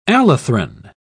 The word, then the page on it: allethrin: British English